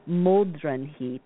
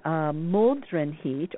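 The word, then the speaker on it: Modraniht: It's actually pronounced something like Moldrenhit, and that's a Germanic holiday, and it's part of Yule festivities.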